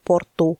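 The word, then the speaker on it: Porto: Вот последний «о» он безударный, поэтому произносится «у», не «поко», а «поко». – А, вот почему город, да, порт пишется «порто», а по-русски «порту».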